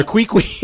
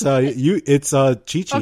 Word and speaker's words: Qiqi: We used to call her Queequee. – Because it's spelled Q-I-Q-I. – And we're like, oh, it's not Queequee? Nope, it's Chi-Chi.